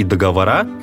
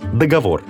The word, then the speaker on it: договор: В книге «Живой как жизнь» Корней Чуковский предсказывал, что варианты «договор» и «договора» станут в будущем нормой литературного языка. Но пока единственно верная литературная норма договор. Причем ударение сохраняется во всех формах.